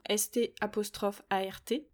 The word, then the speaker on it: Start: La première, ma préférée, il s'agit de l'application Star, S-T A-R-T, ou Start si on la prononce euh, à l'anglaise,